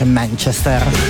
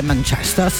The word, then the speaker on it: Manchester: Sì, che poi c'è sempre questa cosa, dove mettere l'accento quando si dice Manchester? C'è chi mi dice Manchester, si dice Manchester, c'è invece chi dice Manchester. Io ho sentito Paul McCartney, che peraltro è di Liverpool, dire Manchester. Ho sentito anche David Beckham dire Manchester.